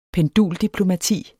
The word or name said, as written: penduldiplomati